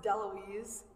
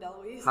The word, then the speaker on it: DeLuise: It's actually pronounced DeLuise. A couple silent vowels on the end. So my name is Jordan DeLuise.